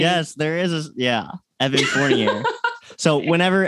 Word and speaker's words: Fournier: Fournier is spelled F-O-U-R-N-I-E-R. Fournier. It's French. F-O-U-R-N-I-E-R.